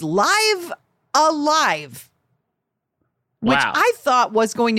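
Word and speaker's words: Live A Live: Because that sounds like, you know, Live Alive sounds like a thing, but it's Live Alive. Like rhymes with, according to Screen Rant, Dive a Dive.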